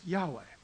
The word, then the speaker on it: Yahweh: But traditionally, typically, people would say the pronunciation is Yahweh. And that's typically what you heard. If you've ever heard his holy name pronounced, you would have probably heard this, Yahweh.